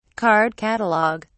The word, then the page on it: card catalog: British English